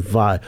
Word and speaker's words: Vach: How do I pronounce your surname? – Like via Johannesburg. – Okay. Alison Vach.